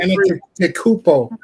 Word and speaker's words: Antetokounmpo: Guinness Gagakopompo. I can't never say his name right. I'm just going to call him the Greek. – Antetokounmpo. – Okay. Guinness Antetokounmpo.